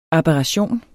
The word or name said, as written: ABERRATIONERNE